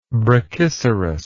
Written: brachycerous

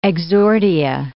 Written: exordia

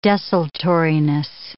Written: desultoriness

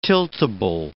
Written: tiltable

